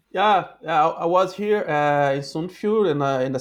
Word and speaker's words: Sunnfjord: Sunnfjord.